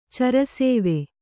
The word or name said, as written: ಚರಸೇವೆ